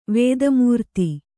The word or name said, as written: ವೇದಮೂರ್ತಿ